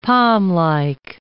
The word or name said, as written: palmlike